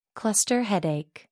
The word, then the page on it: cluster headache: American English